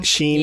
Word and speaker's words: Shein: Shein.